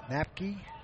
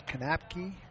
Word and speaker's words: Knapke: He spells his name K-N-A-P-K-E. And I've heard it pronounced differently. Swing and a miss, strike one. Heard it Napke. Now it's Kanapke. He goes by Kanapke.